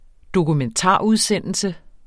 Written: dokumentarudsendelse

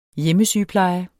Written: hjemmesygepleje

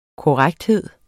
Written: korrekthed

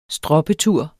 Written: stroppetur

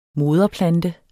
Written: moderplante

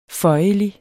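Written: føjelig

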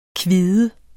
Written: kvide

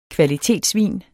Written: kvalitetsvin

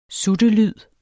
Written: suttelyd

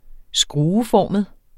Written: skrueformet

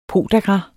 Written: podagra

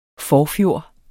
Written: forfjor

